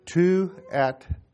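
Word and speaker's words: Thyatira: Because the dictionary, the way it pronounces it, is different than what you read there. It sounds like Tuatira. Tuatiraah. Tuatira.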